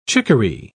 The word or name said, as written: chickaree